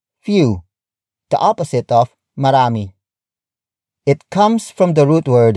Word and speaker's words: marami: For example, marami, marami, marami. Marami is spelled as M-A-R-A-M-I.